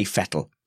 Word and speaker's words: Vettel: I'll give you that, Ian, as long as you say Vettel. Every single time you say Sebastian Vettel, because that is how it's pronounced.